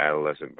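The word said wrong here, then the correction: Adalaide: Bird, or if I'm pronouncing her name wrong, I forgive me. – Adelaide, yeah.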